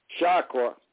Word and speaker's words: Chakra: The Tarka. Is it my saying it right? C-H-A-R-K-A? – Chakra.